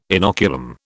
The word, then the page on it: inoculum: British English